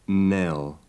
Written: nell

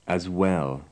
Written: as well

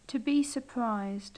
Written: to be surprised

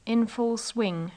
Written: in full swing

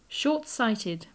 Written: short-sighted

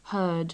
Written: heard

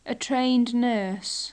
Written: a trained nurse

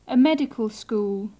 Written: a medical school

